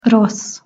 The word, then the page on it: rossz: Hungarian